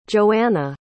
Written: johana

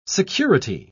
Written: security

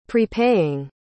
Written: prepaying

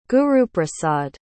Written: guruprasad

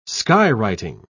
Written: skywriting

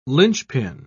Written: linchpin

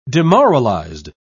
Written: demoralized

